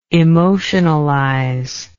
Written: emotionalization

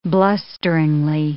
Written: blusteringly

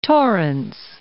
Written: Torrance

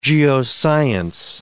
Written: geoscience

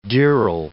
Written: dirl

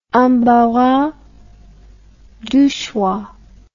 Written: embarras du choix

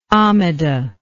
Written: amida

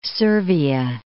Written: Servia

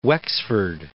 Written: Wexford

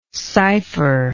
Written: cipherer

